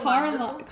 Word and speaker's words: Carlisle: It doesn't look like Carlisle. – Carlisle. I can see how it would be difficult to pronounce. Carlisle. I really can. Silent letters mess up people all the time.